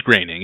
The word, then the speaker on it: Groening: It is Groening. It is not spelled like Groening at all, but Groening, it rhymes with raining.